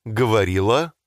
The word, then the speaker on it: говорила: Мама говорила с Брэдом. – Did you say говорила with an а sound at the end so as to rhyme with the word мама? – Говорила.